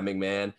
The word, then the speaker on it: McMahon: I don't know how to pronounce McMahon. – This. Yeah, McMahon.